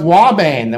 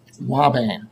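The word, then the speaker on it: ouabain: Turns out that the drug Wabane that we've talked about before, remember this one that sounds like it should be spelled with a W? It is not.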